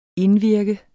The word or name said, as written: indvirke